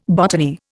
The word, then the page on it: botany: Indian